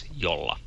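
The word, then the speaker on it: Jolla: The company name Yola has come up in the show a couple of times now, and you've been saying it as if the name is in Spanish. – Yola. – Since Yola is a Finnish company, the proper pronunciation is Yola.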